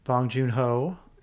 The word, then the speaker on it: Bong Joon Ho: Bong Joon Ho is uh, nice.